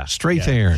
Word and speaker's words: Strathairn: Strathairn.